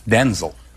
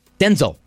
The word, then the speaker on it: Denzel: Mom said you're Denzel, but it's supposed to be pronounced Denzel. – Like D-E-N-Z-I-L, Denzel.